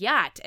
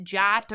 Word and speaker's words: yacht: but she said it the way like you would think you would say it, jot or whatever.